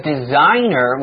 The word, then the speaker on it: designer: Well, a designer, which is spelled D-E-S-I-G-N-E-R, the G is silent, so mm-hmm.